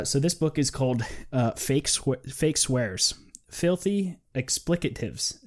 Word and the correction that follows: expletives: Is that how you say it? – Expletives. – Expletives. There it is.